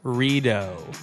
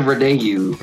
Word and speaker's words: Rideau: Rideau. It is not the Rideau. – Yeah, as I formerly pronounced it, and I'm like super skeptical about pronouncing it ever again, even now. Rideau Canal. Dude, it's such an awesome place. – Rideau. Zach. – Rideau. Rideau or Rideau? – Rideau. – Rideau. – E-A-U in French makes O. – Oh. E-A-U makes, what does it make? – O.